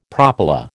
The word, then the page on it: propyla: American English